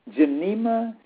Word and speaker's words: Gymnema: You can purchase some Genema Silvestre. Let me spell that for you. G-Y-M-N-E-M-A. And the Sylvester is spelled S-Y-L-V-E-S.